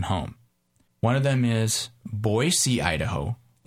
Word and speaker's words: Boise: And as I have learned, the correct pronunciation is Boise.